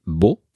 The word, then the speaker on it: Beau: Bo escrito Beau, que si no estoy equivocado se pronuncia así, Bo, que es, eh, es francés y es un, nombre propio, un nombre de pila.